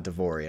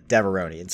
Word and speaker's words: Deveronian: And you look like wait, how do we pronounce it again? It's not Devorian, I don't think I want to say. It's Deveronian.